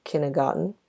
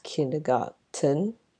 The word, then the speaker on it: kindergarten: So even though it has a T, a letter T in it, it's not pronounced kindergarten according to how it's spelled. Look, I don't know if you can hear it as I'm saying this right now. So I actually checked both the British and American pronunciations, which you can find on online dictionaries these days, and it's a pretty helpful tool to help you with your pronunciation. So it's kindergarten, not kindergarten. Okay.